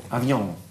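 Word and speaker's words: avion: А как правильно говорится, смотри, еще раз можете повторить. Ди авиа, а? – Авиау. – Авиан. – Авиан. – Авиан, да.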